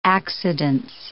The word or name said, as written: accidence